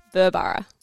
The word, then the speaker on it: Burborough: Burborough.